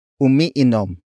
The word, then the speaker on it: umiinom: For example, dehins ako umiinom. Dehins ako umiinom. Dehins ako umiinom. It means, I do not drink, referring to alcohol. Word for word, it's dehins ako umiinom.